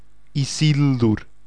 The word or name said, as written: Isildur